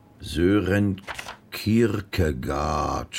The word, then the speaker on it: Sören Kierkegaard: Sören Kierkegaard. Spricht man den so aus? – Ja.